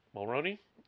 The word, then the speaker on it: Mulroney: Dermot Mulroney. Mulroney? Is that how you say it? Dermot. Yes, it is.